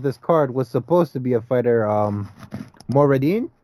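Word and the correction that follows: Morandin: God, I can't remember her name. I can't remember how to say her name. I should say. Let me look up really quick. But point being, Janaisa Morandine, there we go.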